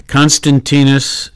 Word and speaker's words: Constantius: Constantius.